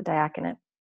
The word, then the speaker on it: diaconate: Yeah, into the diaconate.